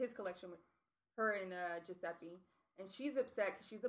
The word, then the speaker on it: Giuseppe: Yeah, Giuseppe.